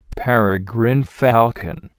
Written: peregrine falcon

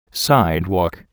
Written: sidewalk

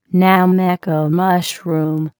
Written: nameko mushroom

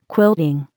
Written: quilting

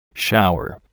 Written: shower

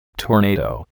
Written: tornado

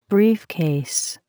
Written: briefcase

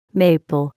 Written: maple

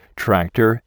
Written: tractor

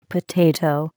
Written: potato

